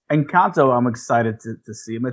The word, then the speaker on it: Encanto: Gotta say it right, y'all. Encanto.